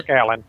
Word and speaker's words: Alyn: I'll get his name wrong, but it's Kirk Allen. Allen. Okay, that's how you spell Allen, A-L-Y-N.